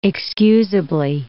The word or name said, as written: excusably